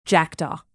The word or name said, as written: jackdaw